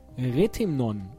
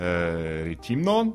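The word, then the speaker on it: Rethymno: Ist die richtige Betonung äh, Retimnon oder ist ähm, Retimnon? Es ist immer, äh, Griechenland ist immer Initial, Akzent. So immer erste Silbe.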